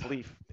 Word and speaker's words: Leif: You say it like life. – Yes. – I've always been told it's Leif, Eric. – Yes. Almost everyone has a friend with a name spelled like mine, and they pronounce it Leif or Leif. And the simple fact is that they're all mispronouncing it. It's Leif. Anyone who's studied Germanic languages or Scandinavian languages know the E-I, I-E combination. And I'm right, and everyone else is wrong.